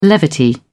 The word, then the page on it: levity: British English